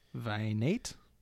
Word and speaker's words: Vinaite: It's V-I-N-A-I-T-E. How do you say that one? Venate.